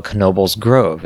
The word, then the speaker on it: Knoebels Grove: And I was at the amusement park that was local to us called Knoebels Grove. And yes, you do pronounce the K.